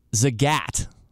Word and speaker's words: Zagat: And that is, I finally figured out how to pronounce Z-A-G-A-T. So Danny definitely said Zagat. Zagat. There was even maybe a little bit of Midwestern twang to the second A.